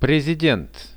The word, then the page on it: prezident: Russian